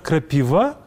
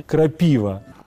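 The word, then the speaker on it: Крапіва: Почему Кондрат Крапива, если ударение по правилам должно ставиться Крапива?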